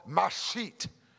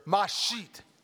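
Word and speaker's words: mashchit: Come on, say it with me, church. Mashit. A little louder. Mashit. You know, if you're not spitting on the guy in front of you, you're not saying it right.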